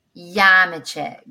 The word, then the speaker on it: Nyamekye: Do you pronounce your name Diana or Yamiche? – Yamiche. Great.